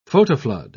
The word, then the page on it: photoflood: British English